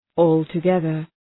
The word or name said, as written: altogether